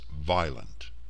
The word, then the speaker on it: Wieland: The name appears of Germanic origin and would thusly be pronounced violent.